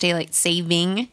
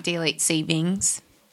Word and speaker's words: daylight saving: Did you know that it's actually pronounced daylight saving and not daylight savings? – Oh.